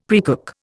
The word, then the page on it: precook: Indian